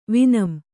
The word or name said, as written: ವಿನಂ